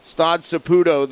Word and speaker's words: Stade Saputo: Mike and Jason will be in, how do we say it, Stade Saputo. Stade Saputo there in Montreal with the call of the game at 7:30.